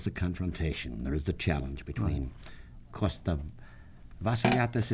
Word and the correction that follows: Avaliotis: is it? – Avaliotis. – Costa Avaliotis. And as difficult a time Laffey has pronouncing the name.